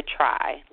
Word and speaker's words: tri: pronounced the try, like we're trying to get better, so we're going to try right.